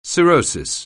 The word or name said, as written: cirrhosis